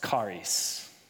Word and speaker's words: charis: And this word right here, grace, in Greek, it's pronounced charis. And it's C-H-A-R-I-S, transliterated.